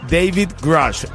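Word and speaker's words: David Grusch: Bueno, otra cosa que tienes que saber en esta mañana, Dave Grouch, eh, que no sé si lo pronuncié bien. David Grush,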